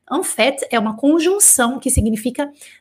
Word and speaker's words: en fait: Com F-A-I-T, como vocês estão vendo aqui, tá bom? Como é, e só que, só que eu, pronuncio esse T. En fait, como eu pronunciei no ditado, en fait, e eu falo T.